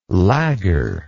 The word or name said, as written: lagger